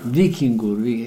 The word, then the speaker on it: Víkingur: Vikingur.